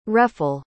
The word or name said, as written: refel